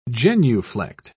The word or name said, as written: genuflect